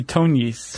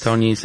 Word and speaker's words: Tognis: Do you know how to pronounce this one? T-O-G-N-I-S? – I would say Tonyes. – Tonyes, that's what I was going to say.